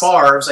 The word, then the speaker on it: Favre's: Favre's. I do know how to say his name.